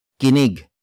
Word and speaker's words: kinig: Makinig comes from the root word kinig, kinig, kinig, which means something that is heard.